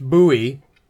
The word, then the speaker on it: Bowie: No American knife design carries the heft or history and legend as does the famous Bowie, pronounced Bowie. – Again, another reference confirming that it's Bowie.